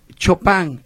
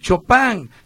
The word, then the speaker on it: Chopin: Y por ahí a los nuevos, la nueva generación de reporteros, es Chopin, no es Chopin, y menos Chopin, ¿eh? O sea, es Frédéric Chopin, se escribe Chopin.